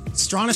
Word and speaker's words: Strachan: Now, his name is spelt Strachan, like the Hall of Famer Strahan with a C, but it's pronounced Strahan.